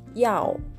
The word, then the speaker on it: 要: で第 4 声なので、上から下に急降下する音になります。やおというふうに上から下に一気に下がる音として発音してください。